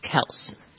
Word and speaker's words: Kelce: That's how a lot of people say it, but the brothers have come out and said that their last name is actually pronounced Kels, even though Kels. Everybody still says Kelsey. – So the E is silent.